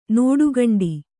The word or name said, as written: ನಡೆಗೋಂಟೆ